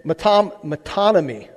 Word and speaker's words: metonymy: You got to say it really carefully because you can say it wrong. It's like saying the word synonym, right? It's metonymy, okay?